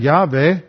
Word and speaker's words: Yahweh: And they would still pronounce that Yahweh, and then it came into English, and we pronounce it Jahweh with a G, and then it became Jehovah.